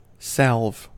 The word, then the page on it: salve: American English